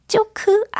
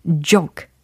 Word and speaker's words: joke: 요거 발음을 하면 어떻게 될까요? 쪼크 아니겠죠. 예, 쪼크 아니고 J로 시작을 하니까 J 이렇게 시작을 하셔야 돼요. J, J 하고 계시나요? 어색하셔도 해보십시오. J 예, 그래서 joke, joke, joke 이렇게 약간 과장스럽게 연습을 해놔야 실전에는 자연스럽게 버무려집니다.